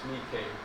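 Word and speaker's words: Nike (νίκη): Nike, or in the Greek it's pronounced Nike.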